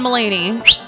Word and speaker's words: Mulaney: So, you know, but John from Pennsylvania referenced the John Mulaney article that I tweeted.